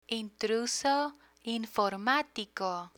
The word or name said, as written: intruso informatico